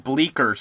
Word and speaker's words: Bleecker: Is that how it's pronounced? – Bleecker Street. – Bleecker Street? – Yeah.